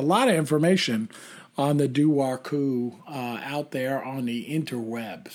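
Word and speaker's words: dobharchú: It's called the Duar Cú. – Duar Cú? – Have you heard of that one? – Exactly. No. – Duar Cú. – Okay. – Which I guess in Gaelic is spelled D-O-B-H-A-R-C-H-U.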